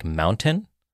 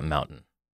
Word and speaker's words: mountain: Most people in Colorado would call it mountain. – Mountain versus mountain. – Yeah. Because you don't actually pronounce the T. So that mountain is called, the term for that is called glottal stop.